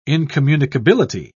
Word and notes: incommunicability: British English

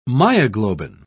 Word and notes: myoglobin: British English

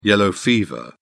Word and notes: yellow fever: British English

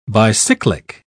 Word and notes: bicyclic: British English

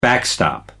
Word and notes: backstop: American English

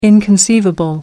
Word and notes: inconceivable: American English

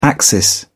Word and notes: axis: British English

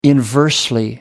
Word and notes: inversely: American English

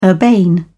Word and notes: urbane: British English

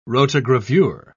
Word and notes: rotogravure: British English

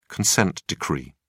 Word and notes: consent decree: British English

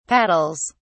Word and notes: paddles: British English

